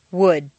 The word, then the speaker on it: would: Once you've found all the L sounds, the good news is that very often you don't even have to pronounce them. Read the following list of words after me. 1. Would, could, should.